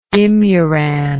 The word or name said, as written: imuran